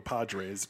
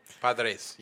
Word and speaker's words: Padres: I'm rolling the R. Padres. Got to flatten your tongue.